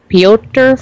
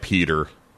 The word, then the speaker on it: Piotr: Oh my goodness, how do you pronounce that? Piotr 49? – I think it's like Peter.